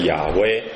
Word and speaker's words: 亚威: 好，中文和本讲耶和华，这是这种发音的翻译呢是有问题的。那比较接近的正确的发音是我们查考出来是亚威，这也是世界上有很多学者去查考的。